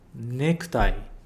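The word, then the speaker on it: necktie (ネクタイ): Necktie. Toimas. Bem, esse k, né? Ele também é um pouco mudo. Ele não fica necktie. É necktie.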